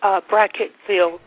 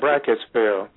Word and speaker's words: Brackettville: Are there any, what's that fort, was it Fort Clark and Brackett, Texas, I believe? Am I saying that right? – Brackettville, Texas, yes, that's where. – Yeah, Brackettville.